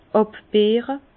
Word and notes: àbbeere: Bas-Rhin; Herrlisheim

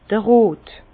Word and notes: d'r Rot: Alsatian; Bas-Rhin; Herrlisheim

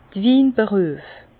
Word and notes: d'r Winberüef: Bas Rhin; Herrlisheim

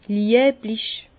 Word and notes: lieblich: Bas-Rhin; Herrlisheim

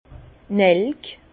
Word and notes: d' Nelk: Bas Rhin; Herrlisheim

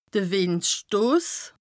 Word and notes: de Wìndstos: Bas-Rhin; Strasbourg

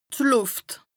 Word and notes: d' Lùft: Bas-Rhin; Strasbourg

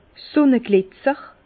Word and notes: Sunneglitzer: Bas Rhin; Herrlisheim

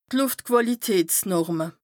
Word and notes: d' Lùftquàlitätsnorme: Bas Rhin

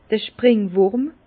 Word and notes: d'r Springwùrm: Bas-Rhin; Herrlisheim

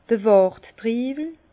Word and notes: d'r Warttriiwel: Bas-Rhin; Herrlisheim

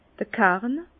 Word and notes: d'r Karn: Bas Rhin; Herrlisheim

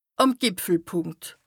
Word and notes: àm Gìpfelpùnkt: Bas-Rhin; Strasbourg

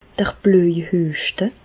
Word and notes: d'r Blöjhüeschte: Alsatian; Bas-Rhin; Reichshoffen

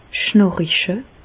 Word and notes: schnàriche: Bas-Rhin; Reichshoffen

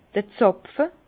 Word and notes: d'r Zàpfe: Alsatian; Bas-Rhin; Herrlisheim

